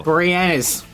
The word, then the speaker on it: Boreanaz: Boreanaz.